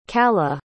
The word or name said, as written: callah